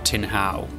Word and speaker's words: Tin Hau: Today we're off to explore the neighbourhood of Tin Hau in Hong Kong, which is pronounced tin like the metal, and how like the question, so how do I get there?